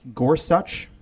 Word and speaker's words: Gorsuch: Uh, first of all, I did look up how to pronounce it properly, and he did say in his testimony that it's pronounced Gorsuch. – That is so frustrating. um, it's a spondee, right? The emphasis is the same on both the first and second syllable.